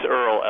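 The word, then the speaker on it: Erle: I don't know if I'm pronouncing the first name correctly, that we've simply outgrown nature. Ben Minteer, what are you talking about there? – Yeah, so it's Earl Ellis.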